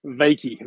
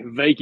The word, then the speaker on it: Wykie: Am I pronouncing your name right? Wykie. – Wykie. Wykie. You got it.